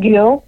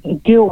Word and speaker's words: Gul: Just like, how do you spell it? – Yeah. – G-U-L.